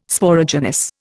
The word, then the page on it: Sporogenous: Indian